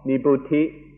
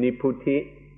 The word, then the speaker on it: nibbuti: The first kind of coolness is called nibbuti, or in the Thai pronunciation, nipputi.